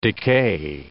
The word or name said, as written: decay